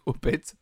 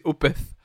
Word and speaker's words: Opeth: Alors je pense pas que ça se prononce Opet. Je pense que c'est Opeth parce que c'est O P E T H.